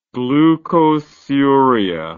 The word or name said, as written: glucosuria